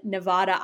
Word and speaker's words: Nevada: And I just Googled Universal Harvester, and it's the little blurb says, Jeremy works at the Video Hut in Nevada, Iowa, but the A is pronounced A-Y.